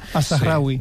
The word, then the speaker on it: Al-Zahrawi: Ya me dirá Miquel si lo he pronunciado bien o mal. – Al-Zahrawi. – Sí. Perfecto, pues pero lo he pronunciado bien. Mal. Es una buena manera de no decírmelo abiertamente. – Sí, no, bien, bien.